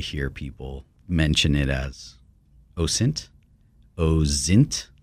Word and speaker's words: OSINT: It's OSINT with a soft S, and the discipline has many things like that that are still somewhat in flux.